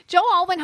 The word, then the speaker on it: Joe Alwyn: Joe Alwyn. – Okay. He looks like a Joey, though. – Joe Alwyn helped her, like, write music.